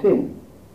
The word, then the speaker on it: Sin: And if we point it on the left hand arm, heraldically the dexter side, then it is Sin.